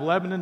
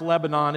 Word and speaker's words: Lebanon: Cracker Barrel is based out of Lebanon, Tennessee. It's not pronounced Lebanon, it's Lebanon, where I'm from,